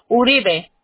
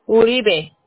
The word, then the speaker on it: Uribe: Uribe. – ¿Ramírez? – No, Uribe. – Ok, ¿cómo se escribe, por favor? – R-I-B-E. Uribe.